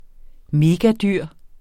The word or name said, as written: megadyr